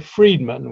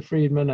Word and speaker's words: Freedman: And my name is Peter Friedman, which is spelled F-R-E-E-D-M-A-N. So if you search for Peter Friedman and then Think Inc.